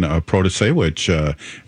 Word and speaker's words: Protasiewicz: And we were really excited to talk to the new Supreme Court judge in Wisconsin, Protasiewicz. And Robert, it took me a couple weeks to be able to say her name before she got on.